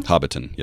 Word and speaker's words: Hobbiton: is it Hobbiton? Hobbiton? – Yeah, Hobbiton. Yep.